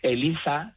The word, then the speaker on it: ELIZA: ELIZA, y además está bien pronunciado con Z, aunque yo vivo en Cádiz, no es que sea ELIZA y yo le llame ELIZA, que ya me lo han dicho por ahí, no, es ELIZA con Z.